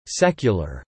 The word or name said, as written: secular